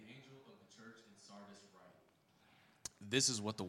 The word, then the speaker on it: Sardis: Sardis.